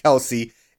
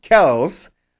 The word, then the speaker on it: Kelce: However, look, Cheetah, Tyreek Hill, and Travis Kelce, apparently his last name is not pronounced Kelsey, it's pronounced Kelce, are just that good that they do not need a third consistent option to demolish defenses.